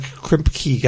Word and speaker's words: Kripke: It's just that Eric Kripke guy.